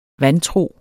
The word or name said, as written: vantro